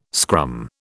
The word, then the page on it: scrum: British English